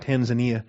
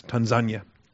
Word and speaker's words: Tanzania: By the way, we learned how to pronounce Tanzania. My whole life we've been pronouncing it Tanzania. And we got there and found out it's Tanzania.